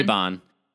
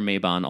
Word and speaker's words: Mabon: That's a perfect thing to put on your Mobin altar. Sorry. wait, is that what? How do you say it? – Mabon. – It's the perfect thing to put on your Mabon altar.